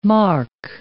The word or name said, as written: mark